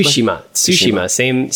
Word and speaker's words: Tsushima: Tsushima.